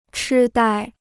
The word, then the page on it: 痴呆: chī dāi